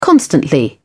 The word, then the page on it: constantly: British English